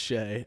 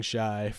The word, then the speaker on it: Shai: So yeah, because now with Shea, by the way, I just learned that his name is pronounced Shay, and I have been saying Shy for God knows how long, so my apologies.